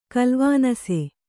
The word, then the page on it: ಕಲ್ವಾನಸ: kalvānasa